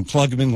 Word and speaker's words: Klugman: My name is Adam Klugman. Let me spell it. K-L-U-G-M-A-N.